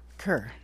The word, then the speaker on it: Kerr: Do you say Kerr or Carr? – Kerr. – Kerr, good. It's American instead of English, but it's spelled K-E-R-R.